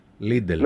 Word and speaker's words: Λίντελ: Είναι Λίντελ, ας πούμε, δεν ξέρω τώρα που τονίζεται. – AJ Λίντελ. Λίντελ.